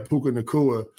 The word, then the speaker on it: Puka Nacua: Did I say it right? Puka oh, Nacua. – Puka, Puka Nacua. Excuse Puka me. Nacua. Yeah, Puka Nacua.